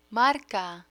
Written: marca

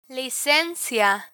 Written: licencia

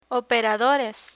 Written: operadores

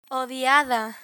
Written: odiada

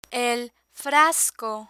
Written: el frasco

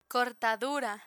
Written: cortadura

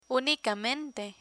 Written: unicamente

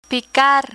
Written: picar

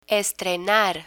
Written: estrenar

